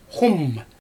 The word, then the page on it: xum: Xyzyl